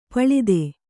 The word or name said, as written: ಪಳಿತ